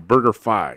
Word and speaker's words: BurgerFi: Kevin Rogers of Vegas Insider says pronounced like Wi-Fi, BurgerFi.